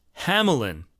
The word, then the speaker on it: Hamelin: His I actually own every Hyperion release that he's put out, And I got the pronunciation of his name from the Japanese kana, which is Amlan. And I was listening to some podcasts where he was interviewed, and they pronounce his name Amlan too. But Russ has discovered a video of Marc-Andre himself pronouncing his name as Hamelin.